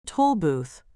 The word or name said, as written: toll booth